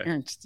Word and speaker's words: Ernst: Am I pronouncing the name correctly? Is it Ernst Tanner, sporting director for the union? – Oh, Ernst. Okay. – Ernst. Yes.